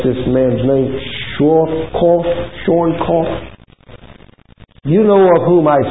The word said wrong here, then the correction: Schwarzkopf: But Schwarzkopf, I know it's Kopf because I've been doing all that.